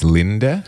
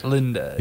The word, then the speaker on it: Linde: Now, I'm pretty sure the next one's pronounced Linde. – Linde. – Yeah, Linde.